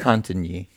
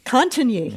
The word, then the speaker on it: Cantigny: And his previous book, First Over, The Attack on Cantigny, how do you pronounce that, Matt? – Cantigny. – Cantigny. Yes. Thank you very much.